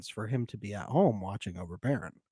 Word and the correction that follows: Theron: It's Theron, I think. – Oh, is it Theron? It's not Baron? – I think it's Theron. – Theron. Okay. Well, I got another name wrong. Add it to the list. – No, it's a tough one. I'm pretty sure it's Theron. – Okay. I guess the TH sounded a little like a B.